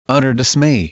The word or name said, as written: utter dismay